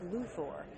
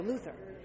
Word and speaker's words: Luthor: It was kind of like when we decided in Superman to call him Lex Luthor as opposed to Luther. – Yeah. – So like, we just looked at each other, Bruce, Tim, and I went, what should we say, Luthor?